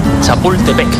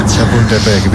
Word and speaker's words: Chapultepec: Chapultepec. Bien, mejor que algún cementerio francés. – Chapultepec, bien, bien, bien.